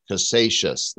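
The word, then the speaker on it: Casascius: Is that how you pronounce it? Cassascius? – Cassascius. – Cassascius. Thank you.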